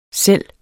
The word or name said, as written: selv